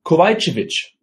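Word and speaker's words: Kowalkiewicz: Rose Namajunas gegen Karolina Kowalczewicz. Ich habe mir sagen lassen, dass das die richtige Aussprechweise ist.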